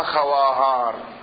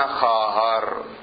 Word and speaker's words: خواهر: يقولون الواو إذا جاء بعدها ألف وكان قبلها خاء تلفظها ألف، مثلا خواهر، خواهر يعني أخت، ما يلفظون خواهر يلفظون خاهر، كأن الواو معدومة.